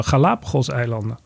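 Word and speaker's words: Galápagos: En die zijn dus ingezet op, eh, op een aantal Galapagos-eilanden.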